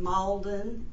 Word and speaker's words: Mauldin: M-A-R-C-I-A, a different pronunciation, and then Lee is L-E-E, and then Malden is M-A-U-L-D-I-N.